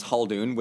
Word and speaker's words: Ibn Khaldun: You can kind of like cheat by just pronouncing it as Khaldun with an H. The Arabic is a little bit different, but I can't speak Arabic, and I just kind of pronounced it as an H, and people said I did fine. So you can just go soft H, and it's okay.